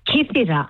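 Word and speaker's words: Kythira: You said it right. Kithira.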